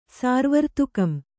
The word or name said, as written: ಸಾರ್ವರ್ತುಕಂ